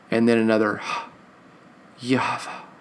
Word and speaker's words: Yahweh: The Lord tells him, he says, my name is it's Yahweh. It's actually the Yod Heh Vah Heh, which is the Yod is the Yah. That's how you pronounce it.